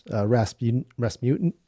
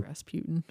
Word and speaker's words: Rasputin: And I think it was only funny because I knew the reference, Rasputin. I'm saying his name wrong. – Rasputin.